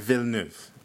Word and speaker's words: Villeneuve: I know Villanueva. How do you say his last name? – I just learned how to pronounce it. It's Denis. – Okay. – Villeneuve. Denis Villeneuve.